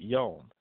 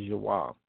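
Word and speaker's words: yawam (יום): All right, a lot of people in modern Hebrew will pronounce it yom. We're talking about the same word. The correct pronunciation from ancient Hebrew is yawam.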